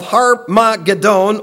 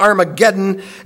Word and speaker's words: Harmageddon: This is a prelude to the Battle of Harmageddon, or Armageddon, as some would have you pronounce it.